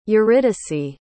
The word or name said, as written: eurydice